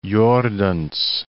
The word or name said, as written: Jordaens